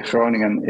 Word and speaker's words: Groningen: If you if you uh, uh, say Groningen in uh, as the locals say it, it actually sounds like that, spoken like that.